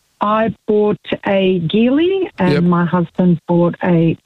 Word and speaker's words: Geely: You know, you're one of the few people that pronounces Geely correctly. I checked. It is a hard G, not a soft G, but most people say Geely.